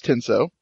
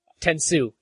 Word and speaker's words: Tenso: Tenso. – Tensu. It's Tensu. His name is Tensu. – I'm going to say Tenso. – I'm pretty sure it's spelled Tenso, but pronounced Tensu.